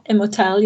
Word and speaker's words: Immortelle: Brennan, you want to steer us into, I might butcher the title, Immortelle? Did I say that right? – Yeah, Immortelle, yeah.